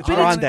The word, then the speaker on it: Grande: You say it like you're ordering her from Taco Bell. – Ariana, by the way. – What is it? – Grande. – It's Ariana Grande.